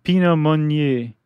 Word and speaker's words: Pinot Meunier: There are three grapes, and they are the Pinot Noir grape, the Chardonnay grape, and how do you pronounce that last one? – The Pinot Meunier.